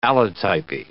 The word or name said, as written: allotypy